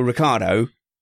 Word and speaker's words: Ricciardo: And I think, Matthew, that Daniel Ricciardo, and I said it right, I did.